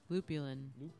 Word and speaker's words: lupulin: Did I say it right? Lupulin? – Lupulin.